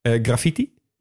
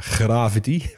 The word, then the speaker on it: graffiti: graffiti. Of zoals de meeste Nederlanders het uitspreken, gravity.